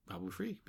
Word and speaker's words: Babu Frik: Is that how you say it? – Babu Freak. B-A-B-U-F-R-I-K.